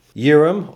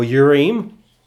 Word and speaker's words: Urim: Urim or Urim, it can be pronounced both ways.